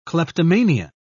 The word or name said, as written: kleptomania